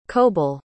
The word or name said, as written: coble